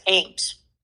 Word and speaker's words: Eames: Yes, Eames. That's Eames. – Oh, the first E is silent? – Yes.